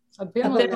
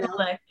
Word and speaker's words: Abimelech: I looked up the pronunciation of the king and I forgot it already. Abba, Ab- Abimelech. Abimelech. – Yeah.